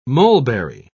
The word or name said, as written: mulberry